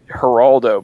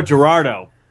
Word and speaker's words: Gerardo: Oh, I thought this was a Celebrity Apprentice, Geraldo, but you were saying it wrong. – No, Gerardo.